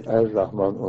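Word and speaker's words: Er Rahman (الرحمن): Birisiniz tamlama olduğu zaman lamlar ra olur. El Rahman kelimesi Er Rahman olarak okunur okuma sırasında.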